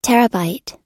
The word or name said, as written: terabyte